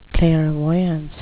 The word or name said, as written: clairvoyance